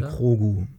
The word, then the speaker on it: Grogu: Wie, da sagt man Krogu. Krogu. Also es gibt einen Ausspracheunterschied.